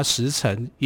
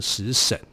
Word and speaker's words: 实沈: 那燕伯，那他的第二个小孩，比较小的小孩啊，叫做实沈，好，或这个实就是实在的实，沈就是。呃，沉默的沉啊，这个字也可以念成沈哈，或者你叫它时辰也可以，但我觉得念时辰有点怪，我把它念成时沈哈。